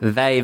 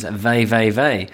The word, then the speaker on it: VVV: They're called Vey. Yeah, that's how you pronounce it. Obviously, to us, it's just VVV, but in Dutch, it's Vey.